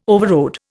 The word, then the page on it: overrode: Indian